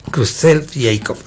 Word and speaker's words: Creutzfeldt-Jakob: Ahora, hay una enfermedad que se llama enfermedad de Creutzfeldt-Jakob. Creutzfeldt-Jakob, así se pronuncia.